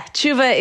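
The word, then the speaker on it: teshuva: Am I saying that correctly? – Yeah, tshuva is the Jewish word for repentance, though it has slightly different meanings, but yeah.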